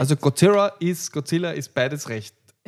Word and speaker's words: Godzilla: Ja, genau, es ist Godzilla und nicht Godzilla. – Ich habe es eher wie ein englisches R, so wie ein R ausgesprochen immer. – Godzilla. Ja, Godzilla. Ja, es ist so mit ein bisschen Zungenschnalz.